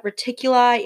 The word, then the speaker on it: Reticuli: Some researchers refer to the case as the Zeta Reticuli incident. I don't know if I'm saying that right. – That sounds but... right. Retic- reticuli. Okay. Yeah. So we'll go with that.